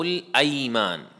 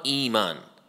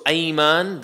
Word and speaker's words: أيمان: Likewise, in Surah Al-Ma'idah, Allah subhanahu wa ta'ala says, لا يؤاخذكم الله باللغو في أيمانكم, ولكن يؤاخذكم بما عقدتم الأيمان, not إيمان, with the fatha. That is why it is important to know the harakat when you pronounce any word of the Arabic language. So ayman, the word ayman is the plural of yameen.